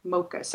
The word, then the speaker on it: mokus: Mocus. M-O-K-U-S.